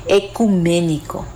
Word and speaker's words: ecuménico: Ecuménico, esta palabra a veces la decimos mal, como yo la dije cuando la pronuncié la primera vez, ecuménico, y en realidad es ecuménico, el concilio ecuménico de Nicea.